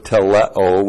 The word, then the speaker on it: teleo: T-E-T-E-L-E-S-T-A-I. Tetelestai. This word is found in John 19:30. It's from the verb teleo, which looks like this. T-E-L-E long O.